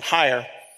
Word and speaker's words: Heyer: And as you know, Heather Heyer, I'm pronouncing her last name, Heyer, you know, she died.